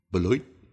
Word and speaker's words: Beloit: about Beloit, I have been told that the locals say the name of their city sounds like a quarter falling into a toilet bowl. Beloit.